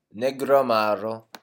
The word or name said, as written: negroamaro